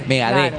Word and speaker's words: Megadeth: Igual que Megadeth. – Con acento en la T. – Claro, Megadeth. – Termina con E y acento en la E. Megadeth.